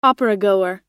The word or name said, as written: operagoer